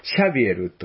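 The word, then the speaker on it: Xavier: 最近は、ザビエルじゃなくてフランス人ですから、シャビエルというふうに発音するのが正しいと言われています。それ普通はですね、そのイエズス会を形成した、作ったイグナチウス・ロヨラと出会って、海外伝道を志す。そして日本に来て、シャビエルはキリスト教を伝えたっていうことですよね。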